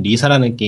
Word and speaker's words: LISA: 아, 마지막으로는 리사라는 게임인데. 그 미사? 리사, 리사. 리사, 어. LISA.